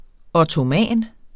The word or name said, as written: ottoman